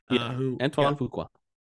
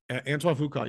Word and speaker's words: Antoine Fuqua: This is directed by Antoine Fuqua, I believe, if I mispronounced that. – Antoine Fuqua. – Antoine Fuqua, yes.